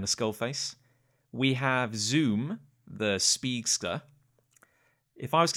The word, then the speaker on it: Zum: His name is spelt Zum, but if you look very closely, there's an umlaut over the U. – Okay. – So it would be pronounced Zoom.